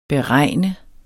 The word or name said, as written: BEREGN